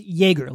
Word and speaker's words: Joerger: And I guess if you're curious, Joerger, spelled what? J-O-E-R-G-E-R, pronounced Joerger, like Joerger, or like a Joergerbaum.